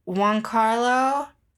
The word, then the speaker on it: Giancarlo: But Giancarlo, did I say that right? – Yeah.